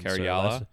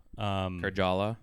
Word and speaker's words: Karjala: Karjala. – So Karjala. – Yeah, team captain. I don't think it's a hard J. Oh. I think it's a soft J.